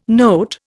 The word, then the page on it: note: Indian